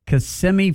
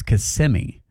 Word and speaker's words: Kissimmee: Kissimmee. What else do you say? Kissimmee. – Kissimmee. – Kissimmee. – Yeah. Kissimmee, a kiss of you. A kiss of you, a kiss of me. But it's Kissimmee.